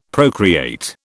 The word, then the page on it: procreate: British English